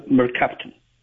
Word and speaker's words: mercaptan: Is it mercaptan? How are we pronouncing it, and what is it? – It's called mercaptan.